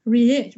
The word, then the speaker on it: Réage: And so this writer Pauline Ray Réage, Réage. I have to like get the French pronunciation of the name.